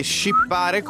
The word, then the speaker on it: shippare: SH si scrive. – Shippare come la nave, ship.